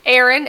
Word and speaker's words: Aaron: Aaron, as in two A's, A A R O N.